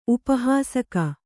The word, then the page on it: ಉಪಹಾಸಕ: upa hāsaka